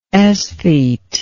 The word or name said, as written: esthete